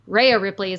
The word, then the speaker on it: Rhea Ripley: Um, also, Ray Rip- Ray Ripley. the excuse me, Rhea Ripley.